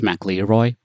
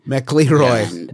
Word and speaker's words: McElroy: It's pronounced McElroy, please. – McElroy, my mistake.